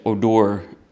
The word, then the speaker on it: Odor: But Odor's he's actually Odor, as they pronounce it.